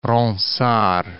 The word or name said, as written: Ronsard